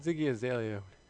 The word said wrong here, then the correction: Iggy Azalea: Or Iggy Azalea.